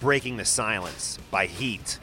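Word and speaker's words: H.E.A.T.: It's spelled H period E period A period T period, but they don't say it like that. As far as I know, they say Heat.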